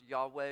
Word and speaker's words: Yahweh: The word that's translated Lord there is a Hebrew word that can be pronounced, is often pronounced Yahweh.